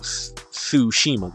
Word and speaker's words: Tsushima: They said Ghost of Tsushima, that's how we pronounce it, is going to be a, it, what it was a, it is a PS, PS exclusive.